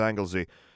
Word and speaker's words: Anglesey: There's supposed to be some patches on the Isle of Skye and Anglesey, but that's all that's left in Britain.